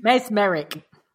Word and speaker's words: mesmeric: There is something mesmeric. What? Mesmeric. How do I say it? – Mesmeric.